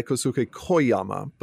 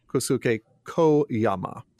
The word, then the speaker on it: Kosuke Koyama: I'm going to say it wrong because that's how I've been saying it, by Kosuke Koyama. But I've been told that Japanese doesn't have open-ended vowels, so it's Kosuke Koyama.